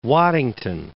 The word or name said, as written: Waddington, Mount